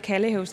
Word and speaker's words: Kalvehave: Der er en vej, der hedder en færge, og så over til byen Kallehave. Og det staves Kalvehave, men man udtaler det Kallehave, så det er ikke, fordi jeg kender mit lokalområde.